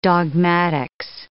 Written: dogmatics

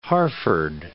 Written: Hertford

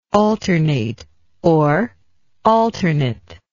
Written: alternateness

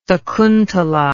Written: sakuntala

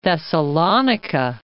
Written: Thessalonica

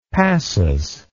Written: passus

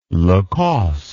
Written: Lacoste

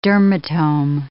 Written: dermatome

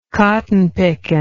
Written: cotton pickin

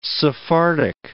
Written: Sephardic

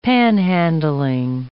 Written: panhandling

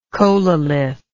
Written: chololith

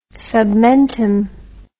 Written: submentum

